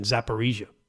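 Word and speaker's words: Zaporizhzhia: Uh, Zaporizhia, I believe I heard this morning on the news, John, is the pronunciation Zaporizhia.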